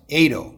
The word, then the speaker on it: Edo: My first name is pronounced Edo. – Oh, I should have asked. – I used to say like, that's okay. I used to say it was like Laredo, like the town in Texas. But I've come to say it's like potato, Edo potato.